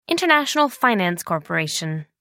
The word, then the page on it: international finance corporation: American English